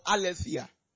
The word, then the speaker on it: aletheia: The word truth, there's the word aletheia. I'll spell it in Greek. A-L-E-T-H-E-I-A. A-L-E-T-H-E-I-A.